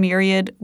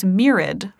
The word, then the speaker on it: myriad: I long thought that the word myriad was myriad for some reason, and now realize it is absolutely not.